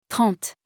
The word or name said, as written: 30